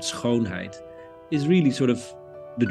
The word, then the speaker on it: schoonheid: Well, there's this famous Dutch word, schoonheid. If you pronounce it and it hurts a little bit, you're saying it right, which has two meanings.